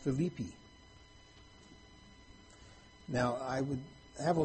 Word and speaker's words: Philippi: It's spelled the same as this, but they pronounce it Philippi. So I'm going to try to refer to this city as Philippi.